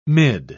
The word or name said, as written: mid